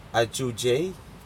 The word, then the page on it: ajujai: Aneityum